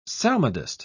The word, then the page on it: psalmodist: British English